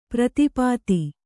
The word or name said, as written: ಪ್ರತಿಪತ್ತು